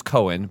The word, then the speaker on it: Coan: And as he was, my name's Cohen, but it's spelled COA, and I'm not Jewish.